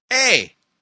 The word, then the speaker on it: eta: Okay, that little thing is called the letter eta. It's pronounced A. Hey, and no, it's not Canadian. Expletive of disassociation.